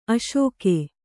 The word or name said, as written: ಅಸಗ